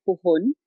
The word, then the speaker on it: puhon: It's called puhon. It's spelled P-U-H-O-N.